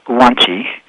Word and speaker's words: guanxi: G-U-A-N-I-Z-I, pronounced guanxi.